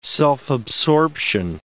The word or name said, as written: self-absorption